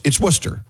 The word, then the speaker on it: Worcester: It's Worcester. – Yes, it's Worcester. – But it'll look like Worcester.